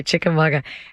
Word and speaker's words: Chickamauga: Chickamauga. Yeah. Yeah, it's a Cherokee word. – Okay, Chickamauga.